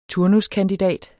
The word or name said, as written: turnuskandidat